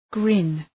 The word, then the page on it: grin: grın